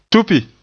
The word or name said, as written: tupi